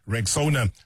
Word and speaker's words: Rexona: A brand that you've trusted for years and years and years is stepping forward with a bold new look, and it's pronounced Rexona.